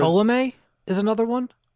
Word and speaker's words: Ptolemy: Yeah, it took me a minute to pronounce it when I first read it, but I'm pretty certain that's Ptolemy.